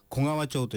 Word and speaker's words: 小川町: 小川町、はい、小さい川と書いて小川町と読みます。